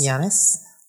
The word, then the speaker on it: Giannis: Giannis.